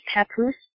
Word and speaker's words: Papoose: So his name is pronounced Papoose? – Yes.